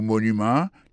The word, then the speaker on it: monument: Pour mot monument, il tombe sur le premier syllabe.